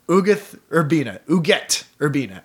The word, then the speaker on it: Ugueth Urbina: So Ugueth Urbina, actually, I believe is the correct pronunciation.